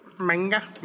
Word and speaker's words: manga: The magma. I can't say it right. – Yeah. – Whatever it is. – Manga. – Manga. Thank you. Manga.